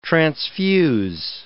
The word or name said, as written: transfuse